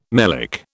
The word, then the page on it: melic: British English